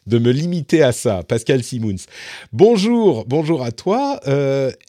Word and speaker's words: Simoens: Est-ce que je prononce bien Pascal Je ne veux pas faire d'impair dès le lancement de l'émission. – Bonjour ah, à toi. bonjour Patrick, presque, c'est Simons en fait.